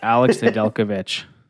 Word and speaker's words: Nedeljkovic: Oh, you're going to jinx me now, but Alex Nedeljkovic from the Carolina Hurricanes.